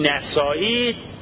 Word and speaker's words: نسائی: یعنی سنن نسائی تلفظ درست بشه به فتح نون، نام روستاشه، نام شهرشه، مال اطراف نیشابور هم بوده، حالا نمیدونم این روستا هست نیست چجوریه.